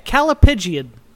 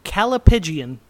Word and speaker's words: callipygian: Okay, Calipigian. – What? – Calipigian. – Calipigian. Can you spell it? – C-A-L-L-I-P-Y-G-I-A-N.